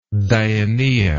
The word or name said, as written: dionaea